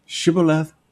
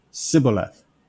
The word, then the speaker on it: Shibboleth: Notice the H is missing. Shibboleth, Sibboleth.